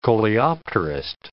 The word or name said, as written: coleopterist